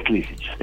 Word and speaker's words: Klisich: Bueno, pero es Klisic. En Yugoslavia siempre se pronuncia primero... fuerte la primera sílaba.